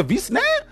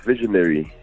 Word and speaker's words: Visionary: There's a V-snare? I'm not saying it right, bro. – V-snare? I'm joking. – It's Visionary.